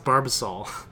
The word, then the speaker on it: Barbasol: How did you pronounce Barbasol? Barbosol? – Barbosol? – Barbasol is like it's one like of the biggest shaving cream. – It's like barbacoa at Chipotle. – It's pronounced Barbasol.